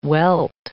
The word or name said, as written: welt